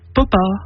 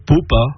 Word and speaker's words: papa: Papa peut se prononcer papa, popa ou poupa.